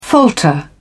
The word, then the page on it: falter: British English